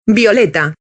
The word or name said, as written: y violeta